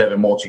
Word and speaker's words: Evan Mulch: If you go to my YouTube channel, Evan, my name's Evan Mulch, you can see it on the screen if you're watching this.